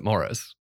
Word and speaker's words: Maurice: It's spelled how I would pronounce Maurice, but it's the UK, so I think they just pronounce it Morris.